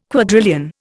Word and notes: quadrillion: Indian

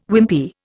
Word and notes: Wimpy: Indian

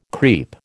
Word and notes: creep: American English

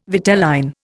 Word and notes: vitelline: Indian

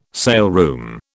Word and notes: saleroom: British English

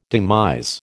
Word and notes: demise: American English

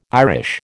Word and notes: Irish: American English

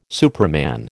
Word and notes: superman: American English

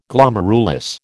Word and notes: Glomerulus: American English

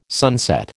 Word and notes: sunset: American English

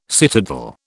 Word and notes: citadel: British English

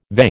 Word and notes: VE: American English

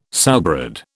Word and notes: sowbread: British English

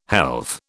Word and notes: helve: British English

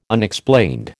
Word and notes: Unexplained: American English

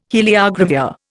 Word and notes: heliogravure: Indian

